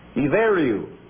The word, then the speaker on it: Ibheriu: One of the ancient names of Ireland is I-B-H-E-R-I-U. Pronounced I-V-E-R-I-U. Iveru.